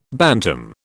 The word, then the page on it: bantam: British English